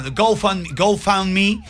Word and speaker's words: GoFundMe: lo bueno de esta plataforma, se escribe GoFundMe. – G-O. – Sí, por tratar de decirlo en español y no pasarlo en inglés, me sonó. GoFundMe.